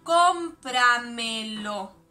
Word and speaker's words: cómpramelo: Es una palabra sobresdrújula. Las sobresdrújulas son las que tienen el acento en una sílaba anterior a la antepenúltima. Cómpramelo es sobresdrújula, tiene acento en la primera O.